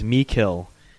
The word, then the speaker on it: Meikle: And I would like to thank our guest, William Milkley. Am I saying that, James? James, correct me. What's his Scottish pronunciation? – I'm not sure. My memory's been wiped. – Oh, he's going to kill us. It is Meikle.